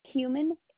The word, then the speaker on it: Huhman: A-N-D-R-E-A. – And your last name? – Human. H-U-H-M-A-N. – You said H-U-H-M-U-N? – Yes. M-A-N. – M-A-N.